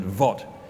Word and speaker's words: Angkor Wat: You might say the Angkor Wat, but they pronounce it Wat, and it's Angkor.